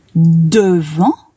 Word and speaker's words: devant: Pour écrire « devant », il faut écrire d'abord la syllabe « de » et ensuite la syllabe « vent ». Le « en », c'est comme le « en » de maman. Et à la fin de « devant », il y a un « t » qui est muet.